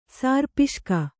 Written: ಸಾರ್ಪಿಷ್ಕ

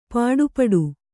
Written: ಪಡುಪಾಟು

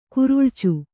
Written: ಕೆರಳ್ಚು

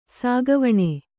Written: ಸಾಗುವಾನಿ

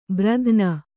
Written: ಭೀರುತನ

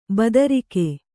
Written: ಬದರಿಕೆ

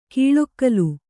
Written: ಕೌಳುಗೋಲ್

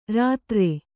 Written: ರಾತ್ರೆ

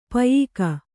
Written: ಪಯ್ಕ

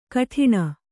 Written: ಘಟ್ಟಣ

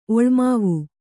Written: ಒಳ್ಮಾವು

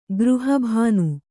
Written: ಗೃಹಭಾನು

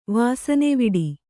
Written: ವಾಸನೆವಿಡಿ

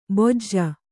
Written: ಭೋಜ

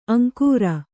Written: ಅಂಗರ

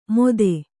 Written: ಮೋದೆ

